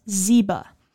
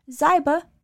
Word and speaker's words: Ziba: In some older videos and interviews, you might hear staff members, mostly myself, refer to Ziba as Ziba, but the family corrected us as to the correct pronunciation of the name.